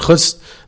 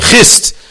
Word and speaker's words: Chust: Today, most Jews who come from Chust don't like it when you pronounce it Chust. They say Chist.